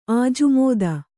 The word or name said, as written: ಆಜುಮೋದ